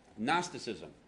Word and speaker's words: Gnosticism: Yeah, the G is silent. I don't know why it's like that, but I didn't make the word, right? But Gnosticism.